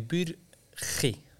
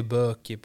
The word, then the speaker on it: Burki: Enligt hans egna uttal så säger han ju Burki. – Ja. – Så att det finns lite... – Det tänker jag inte ens se mig på. – Utan och så Adam Kallenberg ser ju Burki på så här småländska röster, kalmaritiska. Så att det finns lite olika uttal där.